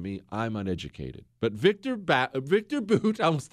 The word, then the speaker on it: Bout: His name is Victor Boot, spelled like Bout, B-O-U-T, but Victor Boot is his name.